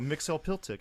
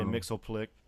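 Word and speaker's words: Mxyzptlk: Mixie's Pitlick is. – Oh, Mixel Piltick, I know him. – Mixel Piltick. Yeah. – Apparently, it's supposed to be pronounced Mixel Piltick, but I just say Mixel Plick.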